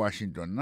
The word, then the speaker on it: Washington: Si on a mis accent sur accent encore, on va voir que le mot Washington, l'accent tombé sur le premier syllabe là.